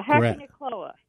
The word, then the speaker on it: Hakonechloa: It's spelled H-A-K-O-N-E-C-H-L-O-A. – Yeah, Hackinacoa.